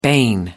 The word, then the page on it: bane: British English